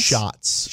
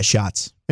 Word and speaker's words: Schatz: ESPN, they asked their analyst, Mike Clay, Aaron, is it Schatz? Is it Aaron Schatz? – Schatz. – Schatz? – But, I mean, hey, to each his own. – Schatz? Is that how you say it? – Yeah, Schatz.